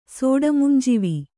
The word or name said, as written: ಸೋಡಮುಂಜಿವಿ